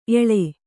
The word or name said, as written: ಎಳೆ